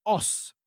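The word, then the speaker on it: os (押忍): Eh, insomma, qualunque cosa, sempre ed esclusivamente la risposta da dare era os, che si scrive in versione, diciamo, con i caratteri latini Osu, correggimi se sbaglio, Giusto. e si pronuncia Os.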